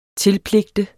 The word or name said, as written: tilpligte